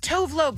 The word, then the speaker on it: Tove Lo: It's Tove Lo, but then she said it was like Tuvalu or something.